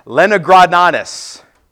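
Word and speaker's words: Leningradensis: The Codex Lenigradensis, yes, that's how you pronounce it, was written and copied by a man named Samuel ben Jacob.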